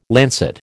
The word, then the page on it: lancet: American English